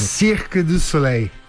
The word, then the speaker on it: Cirque du Soleil: Cirque du Soleil.